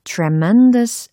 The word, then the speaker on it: tremendous: T-R-E-M-E-N-D-O-U-S 발음은 tremendous, tremendous, tremendous 아니고요. tremendous, tremendous 그래서 I was under tremendous stress 이와 같이 세 부분을 강강강 찍어주시는 거 기억하시면 좋을 것 같아요.